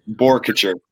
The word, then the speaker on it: Boerkircher: Is it Bocature or Boerkircher? Boerkircher.